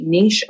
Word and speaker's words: niche: It's like N-I-C-H-E, niche or niche. Yeah. Niche.